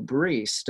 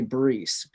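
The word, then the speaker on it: Brise: And then you have Adele Bryce or Adele Brees, depending which way you want to say it. – How do you say it? – I usually say Brees because...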